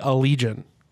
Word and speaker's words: Allegaeon: Again, you need to call them the Allegaeon. Allegaeon. Instead of the Allegaeon Army. Allegaeon. – Why? – Like Legion. L-E-G-I-O-N.